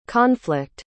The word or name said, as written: conflict